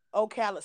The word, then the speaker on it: Ocala: Ocala.